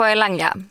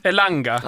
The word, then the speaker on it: Elanga: På Elanga. – Elanga.